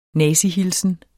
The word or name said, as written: nazihilsen